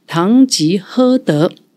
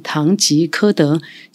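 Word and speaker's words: 唐吉訶德: 没错，这就是今天我们要当大家特别聊聊的唐吉诃德。我没有念错啊，一般团员都会把它发音念成唐吉诃德，其实这个字要念喝喝东西的喝。那另外它的名称，例如。金安殿堂、基安殿堂，或者是东基后代，唐吉诃德啊！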